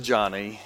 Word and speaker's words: Joni: It looks like Joni when you see her name, but she pronounces it Johnny.